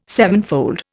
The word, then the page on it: sevenfold: Indian